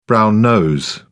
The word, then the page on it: brown-nose: British English